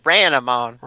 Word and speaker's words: Ranamon: She was like Renamon, but she said it Ranamon. I think it's spelled differently. It's like R-A-N-A.